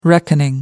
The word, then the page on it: reckoning: American English